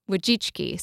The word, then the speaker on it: Wojcicki: Wojcicki. – So Anne Wojcicki.